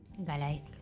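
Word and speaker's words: Gallaecia: Ah, a ver, hay otro temilla que queríamos contar, que es cómo se desarrolló la primera edición de Galleacia. Lo dije bien, ¿no? – Galaecia, pero... – Galaecia, ¿cómo? – Galaecia.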